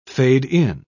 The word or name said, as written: fade-in